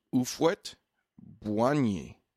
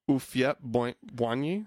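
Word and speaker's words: Houphouët-Boigny: So in 1985, Houphouet-Boigny... God, I know I'm just butchering that. – You know what? I looked it up, and it looks like it's pronounced Ufwet Bwanyi. Bwanyi. – Ufwet Bwanyi? – Ufwet Bwanyi.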